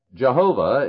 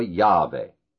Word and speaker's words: Yahweh: Jehovah is a medieval rendering, and almost certainly does not coincide with any pronunciation of the name ever used in Hebrew. Modern scholars generally plump for Yahweh.